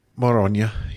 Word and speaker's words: Maragna: How do you suggest her name is pronounced in Italian? Maragna? – Maragna, yeah.